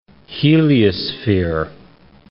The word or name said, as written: heliosphere